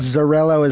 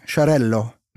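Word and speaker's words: Xarel·lo: Zorello is how I pronounced it before I heard a Spanish speaker or a Catalonian winemaker say, Charello.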